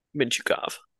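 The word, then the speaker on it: Mintyukov: and that's Pavel Minyukov. Am I saying that right? – Minchukov. – There we go.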